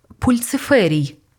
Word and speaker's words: Пульциферій: «Пульциферій?» «Пульциферій», – виправив Ньют. «Тверда ц».